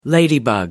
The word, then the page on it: ladybug: British English